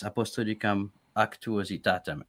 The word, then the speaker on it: Apostolicam Actuositatem: Yes, Apostolicam Actuositatem.